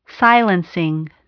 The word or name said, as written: silencing